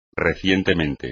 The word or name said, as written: recientemente